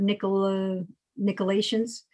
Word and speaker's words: Nicolaitans: He didn't say he hates, how do you say that word? Nicolaitans? – Yes. – He's saying he hates the deeds of the Nicolaitans.